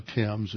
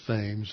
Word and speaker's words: Thames: That takes a while to get used to the, it's not the Thames, it's the Thames. That's how they pronounce it up there.